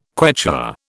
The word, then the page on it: Quechua: British English